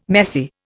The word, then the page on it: messy: Indian